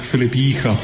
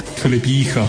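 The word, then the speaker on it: Filip Jícha: Así que vamos a escuchar cómo se pronuncia. – My name is Filip Gicha. – Gicha. – Filip Gicha. – Filip Gicha. – Gicha, muy bien.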